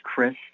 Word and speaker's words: Chris: Yeah, my name is Chris Gambinski, C-H-R-I-S. G-E-M-B-I-N-S-K-I, and I'm a professional treasurer and compliance specialist.